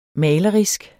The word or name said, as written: malerisk